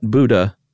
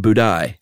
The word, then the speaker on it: Budai: 10th century Chinese monk whose name was not Buddha, but Budai. – Okay, so that was his nickname. His real name was Jizu. – Yeah. – I think that was my attempt at it. – So Budai is spelled B-U-D-A-I, uh-huh.